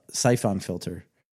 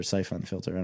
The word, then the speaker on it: Siphon Filter: It was just Siphon Filter. I've never heard anybody say Siphon. – Well, I put the wrong emphasis on the wrong syllable, so kill me.